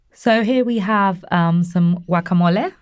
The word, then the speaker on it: guacamole: Guacamole. – Okay, see, everybody else, like me, guacamole, you call it, you don't pronounce the G.